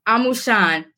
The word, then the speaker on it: Amusan: Amusan, Chief yes.